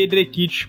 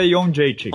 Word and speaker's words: Jędrzejczyk: eu prefiro porque é um abrasileirado, mas assim, se você pegar e botar no YouTube ela pronunciando, ela pronuncia Jędrzejczyk.